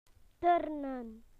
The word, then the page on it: to do gymnastics: British English